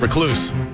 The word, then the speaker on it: recluse: Recluse is how you say it.